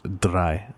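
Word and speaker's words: Drei: Question Dre. For this question, you can also give a non-trans... – It's pronounced dry.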